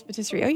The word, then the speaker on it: pâtisserie: Yes, patisserie. You said that well.